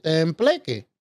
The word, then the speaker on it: tembleque: B as in boy, L-E-Q-U-E. – Okay. I was pronouncing it wrong. – No, you said it right. I spelled it the way you said it. Tembleque.